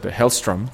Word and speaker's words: Hellstrom: y tiene a una hija que se llama Satana Hellstorm. Hellstrom, siempre lo digo mal.